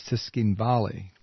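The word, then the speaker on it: Tskhinvali: I've been told here that the pronunciation of the key city in South Ossetia is Tiskin Valley.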